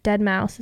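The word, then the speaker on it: Deadmau5: Dead Mouse, is that how you say it, right? – Mm-hmm.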